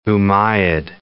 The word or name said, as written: Umayyad